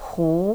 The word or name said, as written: hu